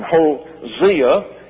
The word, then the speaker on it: Hosea: And Hosea, as it's correctly pronounced, according to all of my dictionaries, tells us this.